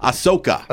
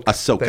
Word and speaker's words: Ahsoka: Ahsoka. – Ahsoka. – Ahsoka.